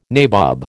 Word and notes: nabob: American English

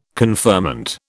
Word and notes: conferment: British English

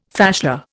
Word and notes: fascia: Indian

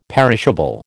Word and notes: perishable: American English

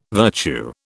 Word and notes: virtue: British English

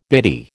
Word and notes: bitty: American English

